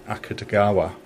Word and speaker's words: Akutagawa: And it's based on the writings of probably the biggest ever Japanese author, whose name I'm going to try and pronounce. It's Ryunsuke Akutagawa. – Exactly.